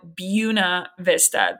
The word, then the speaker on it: Buena Vista: It's spelled Buena Vista, but us locals call it Buena Vista.